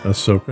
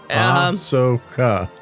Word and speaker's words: Ahsoka: One is Rangers of the New Republic, and the other is Ahsoka. – Ahsoka. I don't know why. When I look at it, I can't. – It's pronounced just the way it's spelled. – Sure. – Ahsoka.